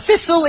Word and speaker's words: thistle: How do we pronounce the word thistle? Pues tenemos que sacar la lengua, y mucho. Tal y como se hace thistle. al pronunciar el nombre de esta magnífica ciudad, ¿no?